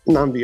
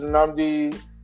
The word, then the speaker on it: Nnamdi: Nnamdi, your time starts now. – Okay, this is difficult to follow up, but I'll try. My name is Nnamdi. You can find me on Twitter at Nnamdi Jugo. Actually, you can find me everywhere at Nnamdi Jugo. So it's Nnamdi and J-U-U-G-O.